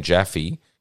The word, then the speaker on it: Jaffe: Like for example, some of the um, AIs will will pronounce my name Jaffe correctly, and some will say jo- or Joff or and so you got to just phonetically spell it as in you know J A F J A F F E E E or something like that.